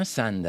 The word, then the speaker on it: son: The same as sun, no? Sun, the star. I sun, I have two daughters. No tinc dos filles. I don't have a son with O. Sun, the fill.